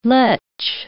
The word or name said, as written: letch